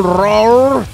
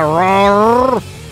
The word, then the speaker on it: roar: Roar, que nos enseñó nuestro amigo Ratón Roquero cómo se pronuncia roar. Es muy fácil de pronunciar roar, porque solo tienes que imitar el rugido del león.